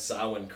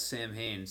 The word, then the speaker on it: Samhain: There's just little stuff like that, but then, I like Halloween 3 too, because they actually pronounce Samhain correctly. Loomis kind of butchers, him and the cops butcher Samhain, which for years I always thought it was Samhain, you know? – That's a good point. – Yeah, because it's, you know, Well, it's like when people talk about Danzig's band, right? They're always like, oh yeah, it's Samhain, it should be pronounced Samhain, right? – Yeah, Samhain, yeah.